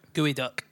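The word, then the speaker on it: geoduck: there's now it's spelled G E O D U C K. G E O D U C K. Goo duck. Gooey duck.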